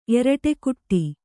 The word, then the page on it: ಎರಟೆಕುಟ್ಟಿ: eraṭekuṭṭi